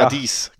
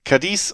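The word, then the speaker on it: Cádiz: Das ist das Duell zwischen Cadiz und Sevilla. Ist das richtig ausgesprochen? – Ah, ja. – Cadiz? – Ja, besser? – Cadiz. – Cadiz und Sevilla treffen aufeinander, das am 3.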